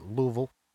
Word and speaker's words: Louisville: August 16th, 2016, he is caught drinking just before a flight to Louisville, I believe they pronounce it Louisville, Kentucky. – Okay. Yeah, Louisville.